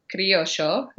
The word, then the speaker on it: Criollo: And their breed is Criollo, is how you say it in Argentina.